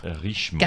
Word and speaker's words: Richemont: Under the distribution arm of the Rupert family's luxury goods company, Richemont, which owns Cartier and Montblanc, among other brands. Blands. – What's the distribution line called? – Richemont. – There we go. – Richmond. – Under what group? – Richemont.